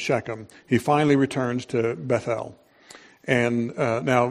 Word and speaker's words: Bethel: I say Bethel because I was listening to many preachers, and one of them pronounced it that way because he put emphasis on the last syllable, E-L, because that's God's name. And so this is the house of God. And, you know, so there's more emphasis on God than on the house.